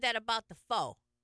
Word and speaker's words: Pho: Pho, how it's properly pronounced, is rice noodles.